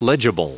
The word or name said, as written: legible